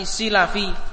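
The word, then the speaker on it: Silafi (السلفي): Jadi jangan salah bacanya. Ada satu imam yang namanya atau nisbahnya bukan Salafi tapi Silafi, yaitu Al-Imam Abu Thahir As-Silafi.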